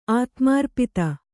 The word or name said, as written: ಆತ್ಮಾರ್ಪಿತ